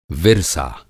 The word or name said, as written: विरसा